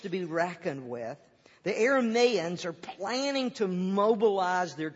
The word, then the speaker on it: Arameans: Arameans is correct. But for some reason I want to say, never mind.